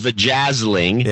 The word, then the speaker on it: Vajazzling: Oh, you didn't pronounce it right. – Yeah. Oh, yeah, that's what it is. – Yeah. – Give me an example of the book. – Even though it's pronounced Vajazzling.